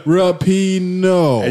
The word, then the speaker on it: Rapinoe: Rapinoe. – That's a hard name, man. – I mean, that's a big name. – Rapinoe.